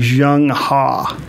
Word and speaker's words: Zheng He: The actual pronunciation of his name, even though it's spelled Zheng He, is actually Zheng Ha.